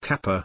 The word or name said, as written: kappa